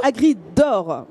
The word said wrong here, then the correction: Agriodor: Agriodor. – Agriodor. On va y arriver.